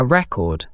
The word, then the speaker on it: record: A record, note the different pronunciation, is a song or sometimes an album.